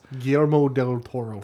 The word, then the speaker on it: Guillermo del Toro: Dead Man, which is visually Guillermo del Toro. I'm butchering his name. I apologize. – Guillermo del Toro.